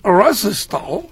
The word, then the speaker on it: Resistol: Wichín, el nombre Resistol, que se pronuncia en inglés Resistol.